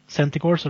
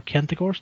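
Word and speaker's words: Centigor: And that's the Centigors or Kentigors, depending on how you want to pronounce it.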